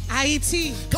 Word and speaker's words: Ayiti: Come stand right here in front of the camera and say after me, say, Haiti. Ayiti. Come on, say it like I'm saying it.